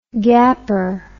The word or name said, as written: gapper